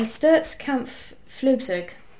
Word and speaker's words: Sturzkampfflugzeug: And now you're going to have to forgive my pronunciation, anyone who's German listening. It's the Sturzkampfflugzeug. – Mm-hmm. I think that wasn't too bad.